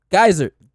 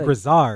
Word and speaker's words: geyser: going forward, it's just people need to geyser. That's how you say it. – My bad. Geyser. I knew there was no R. – Geysered. – Yeah. – No, you were saying Grizzard.